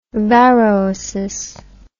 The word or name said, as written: varroasis